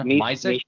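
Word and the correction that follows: Myšák: I had a chat with Trevor about, you know, how you pronounce it, and I think it's Mishak. You know, I said, well, I wouldn't want to be called my sack, you know? – No!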